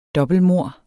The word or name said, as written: dobbeltmord